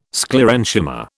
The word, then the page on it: Sclerenchyma: British English